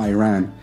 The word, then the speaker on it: Iran: You say you support the Iranian people, but you're pronouncing our country's name wrong. Our country is named Iran, not Iran.